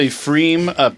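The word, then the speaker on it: Pfriem: The P is silent. It's a Freem Pilsner from Hood River, Oregon.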